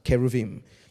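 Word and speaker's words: keruvim (כְּרוּבִים): So you have cherubim, or keruv, actually is how it's pronounced, keruvim.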